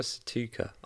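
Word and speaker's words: Sztuka: How do you say that last name? – Why do you think I'm... Casper Stuka? Stuka? Casper Stuka, I would say. – Okay, spot on. That's very good.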